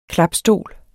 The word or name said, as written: klapstol